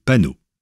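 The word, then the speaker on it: paonneau: Idem pour leur petit, qui pour un paono dans le texte, se dit panneau.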